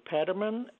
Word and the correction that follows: Fetterman: Fetterman. Fetterman. F. F. – Okay. – It's the F word.